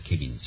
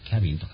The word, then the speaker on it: cabin: ですから、箱舟で 2 回続けて書いたように、アンクル・トムス・ケビン、アンクル・トムのケビンっていうのは本当キャビン、 CABIN ですね、キャビンという発音なんですね。日本語ではケビンに近くなるからキャビンと書きます。